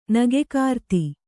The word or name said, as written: ನಗೆಕಾರ್ತಿ